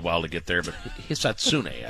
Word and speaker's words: Hisatsune: So Hisatsune, I believe, is how you say it.